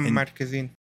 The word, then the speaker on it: Marquezine: Bruna Marquezine. Yeah, Bruna Marquezine.